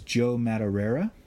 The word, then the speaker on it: Joe Madureira: It's Joe Madureira. – Okay. – That is how he pronounces it.